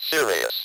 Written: serious

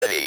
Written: ready

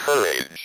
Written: courage

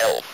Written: elf

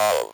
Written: of

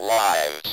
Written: wives

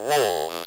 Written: wolves

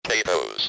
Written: potatoes

